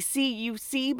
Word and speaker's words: CUC: The currency would be CUC, but they pronounced it cuc.